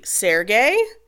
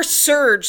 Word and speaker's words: Serge: That can either be Sergey or Surge, like the Yeah.